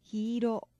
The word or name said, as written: hi iro